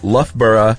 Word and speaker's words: Loughborough: My free tip for you is that if you ever have to discuss a place called Loughborough in Leicestershire, it's pronounced Loughborough in Leicestershire.